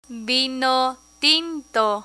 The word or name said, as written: vino tinto